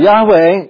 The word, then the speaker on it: Yahweh: But Yahweh is, what we believe is the Hebrew pronunciation of the divine name, although we're not sure.